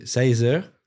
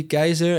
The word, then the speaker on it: Kaiser: Yeah, in French, they say Caesar. And I always say no, it's actually Kaiser.